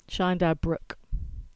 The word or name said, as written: Chyandour Brook